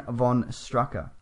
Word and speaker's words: von Strucker: But he says it's F-O-N, so von Strucker. S-H-T-R-O-O-K-E-R. – Von Strucker.